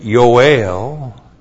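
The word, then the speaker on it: Yoel (יואל): One, the English the English title Joel is a transliteration of the Hebrew Yoel. That's Y, long O, Aleph, E, L, Yoel.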